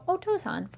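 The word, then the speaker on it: Otōsan: But if you've heard it on anime, we did Okasan for mom for Mother's Day, so Otosan for father. Yay! – Otosan, I gotta call you.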